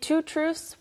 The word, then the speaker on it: truths: To, 원래는 그냥 truth 발음이잖아요. – All right. – 그런데 two truths 발음하기가 너무 어려워요. – Yeah, two truths. – 네, 그래서 truths. – 그냥 S가 더 강조되는 느낌. – Yeah. – Two truths. – Two truths. – Two truths. – Truths. – 네, 그렇게 발음이 됩니다.